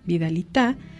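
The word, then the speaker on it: vidalitá: En sus versos, muchas veces, la vidalita se caracteriza por intercalar la expresión vidalita, acentuada en la última sílaba, vidalita, para producir un efecto de que el cantante le habla a la vidalita.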